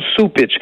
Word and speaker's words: Cupich: C'est C-U-P-I-C-H, mais on prononce Soupitch.